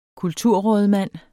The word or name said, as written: kulturrådmand